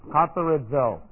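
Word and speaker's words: katharizō: Katharizo. And this is K-A-T-H-A-R-I-Z long O. Katharizo.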